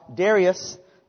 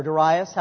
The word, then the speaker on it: Darius: His nephew, who was a co-regent with him for a while, Darius, the guy, or Darius, how you want to pronounce it.